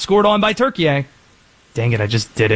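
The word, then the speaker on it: Türkiye: It's yeah, that it that is how it's spelled. But they now say the pronunciation is Turkey.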